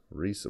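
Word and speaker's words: Reiss: Hat Reese seine Potenz. Rice. – Rice. Rice wird das ausgesprochen.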